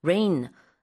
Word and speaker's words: rein: 그래서 rein, 여기서 R-E-I-N 이렇게 쓰는데요. 발음은 R-A-I-N이랑 똑같죠.